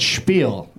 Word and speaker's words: Spiel: I want you to give it the Jewish pronunciation. It's Spiel.